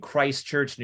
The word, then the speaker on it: Christchurch: You lived in Australia until you were six, and then you lived in Christchurch, New Zealand, if I'm saying it correctly.